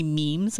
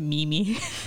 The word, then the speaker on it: meme: I used to pronounce it meme-y. And then, a younger millennial, a millennial told me how to, my niece told me how to pronounce it wrong. – It's memes? – I know it's memes.